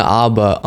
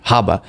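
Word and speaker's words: Habba: So on the subject of this case and it being appealed, I have our favorite lawyer, a clip of her, Aaron, Alina Abba on, or Haba, somebody said, please pronounce the H, Haba, on Newsmax, giving a pretty powerful bit of analysis as to what the grounds of appeal are for this fraud case.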